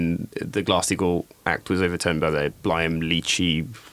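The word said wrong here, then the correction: Gramm-Leach: Have I said that right? Blyam. These regulations. No, Graham Leach.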